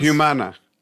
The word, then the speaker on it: Humana: And I have listened to a little bit of Vox, how is it pronounced? – Humana. – Humana. Okay.